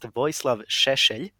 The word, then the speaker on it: Vojislav Šešelj: It's pronounced Vojislav Šešelj. – Vojislav Šešelj. – Yeah, it's not a sound you have in English, but okay.